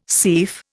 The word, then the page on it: seif: Indian